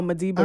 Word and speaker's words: Madiba: The I don't know how you pronounce it. The Malambe Rhythm? – Oh, Madiba Rhythm.